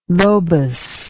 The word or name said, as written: lobus